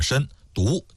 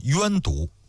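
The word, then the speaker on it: 身毒: 申就是身体的申，毒就是毒品的毒。关于这个读音呢，有好几种说法，有些书上包括央视的纪录片上把它读成是冤毒，但是还有一个专门的文章啊，就说央视里边这个冤毒呢是读错了，就直接读作申毒就可以了。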